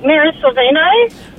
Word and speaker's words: Mira Sorvino: Would it be, I'm not sure how to pronounce her name, is it Mira Sorvino? – Ah, yeah.